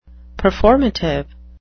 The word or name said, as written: performative